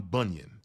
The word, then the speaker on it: Bunyan: And so what I love a quote by John Bunheim, who says this. I'm sorry, I'm butchering his name. John Bunyan, that's his name.